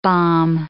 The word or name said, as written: bombe